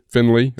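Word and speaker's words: Findlay: Is it Finlay? Finlay? Is that how you pronounce Finlay. – It? – Finlay. – Finley. Okay. Ohio, number four offensive tackle in the country.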